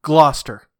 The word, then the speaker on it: Gloucester: Well, that's like, growing up in New England, in Massachusetts, there is a town, and the way that is spelt is Glouceter, but it's pronounced Smith. – Gloucester. – Gloucester? – Yes.